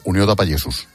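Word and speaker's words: Unió de Pagesos: Lo he dicho bien, ¿no? En buen catalán, Unió da Pallesos. – Sí, sí.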